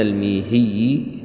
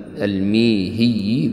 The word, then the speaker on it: الميهي: قال عن شيخنا الميهي هكذا تنطق ذي الكمال بكسر الميم، الميهي